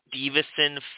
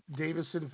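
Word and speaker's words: Deiveson: I don't know how to say his first name. You do. It's Davison Figueiredo. – Davison Figueiredo.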